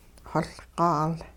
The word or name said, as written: xoLqaal